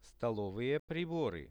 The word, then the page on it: stolovye pribory: Russian